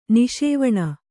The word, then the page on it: ನಿಷೇವಣ: kannada